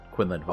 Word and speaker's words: Quinlan Vos: Quinlan Vos.